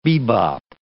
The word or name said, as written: bebop